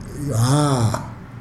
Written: haa

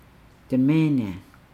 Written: demeenaa

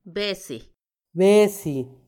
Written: besi

